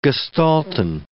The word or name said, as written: gestalten